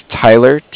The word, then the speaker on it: Tyler: My name is Tyler, T-Y-L-E-R, and okay. – I'm calling with Cardiff. It's spelled C-A-R-D-I-F-F, as in Frank Frank.